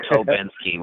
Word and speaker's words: Stobinski: I say Stobinski, but Stobinski is fine. So a couple of times I said Tobinski with a silent S.